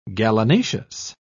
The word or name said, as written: gallinaceous